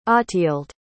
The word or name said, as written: otthilde